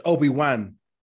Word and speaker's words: Obi-Wan: It's Obi-Wan. Yeah. W-A-N. – Yeah. – But it's pronounced one. They say one though, Obi-Wan.